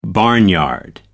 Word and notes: barnyard: American English

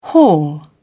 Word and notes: hall: British English

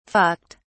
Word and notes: fucked: British English

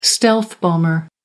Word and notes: stealth bomber: American English

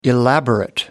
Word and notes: elaborate: American English

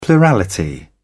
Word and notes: plurality: British English

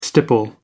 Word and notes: stipple: American English

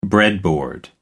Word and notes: breadboard: American English

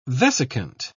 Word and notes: vesicant: British English

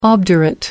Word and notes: obdurate: American English

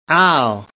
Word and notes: ow: American English

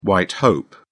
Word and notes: white hope: British English

